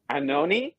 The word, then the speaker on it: ANOHNI: Uh, uh, and I never know how to say this. I I should probably look into it, but it's uh, ANOHNI ANOHNI it's spelled. It's all capital. A N O H N I.